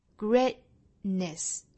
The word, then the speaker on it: greatness: Greatness 摆出这个 t 的口型，也就是舌尖在上齿龈的位置去抵一下，然后再去发后面的 ness 的发音。 Greatness 顿这么一下，greatness, greatness, greatness，后面的这个 ness, n e s s，可以把它发成一个。这个元音是 i 的 ness，也可以选择发里面是一个 schwa sound ness， greatness， greatness 也是可以的。